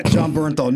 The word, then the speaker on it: Bernthal: And the main guy was John Bartha. Did I say that right? Bartar? – John Bernthal.